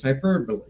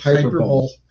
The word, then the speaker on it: hyperbole: I know when I hear it, I go, I know what that means, hyperbole. – Okay. – But when I saw it written down, I pronounced it hyperbole. – Of course you did. – I was going around saying hyperbole,